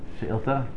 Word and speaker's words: Shilta: How do you say singular Shiltos? Shilta? Okay. Shilta Chaf Aleph.